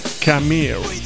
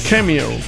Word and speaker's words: Cameo: E il singolo in questione per questi Gun si chiamava World Up, una cover rock di un vecchissimo pezzo dei Cameo, o Cameo, per dirla con la giusta pronuncia anglosassone, Cameo, Cameo.